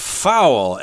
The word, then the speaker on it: fowl: Uh, that's a kind of fowl, F O W L, uh, which is another another word for birds. – Right. It's the same pronunciation as a foul in sports, F O U L, but wow, it's completely different.